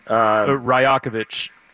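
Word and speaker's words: Rajaković: So, yeah, that and Darko, and you pronounce his last name. – Ryakovic.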